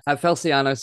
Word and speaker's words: Felciano: I am joined by the magnificent Mano Feliciano. Felciano, sorry, I just added an I in there.